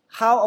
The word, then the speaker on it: how: How about you? 这个 H O W 这个字母我们会读成 how。它那个 O W 这个字母的发音你要注意一下，它是 R 加 U 的发音，ow，就别人掐你 ow，不是 O 啊，不是 O 的发音，是 R O 啊，ow 啊，你掐 ow，就 how， how about you？